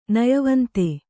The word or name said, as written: ನಯವಂತ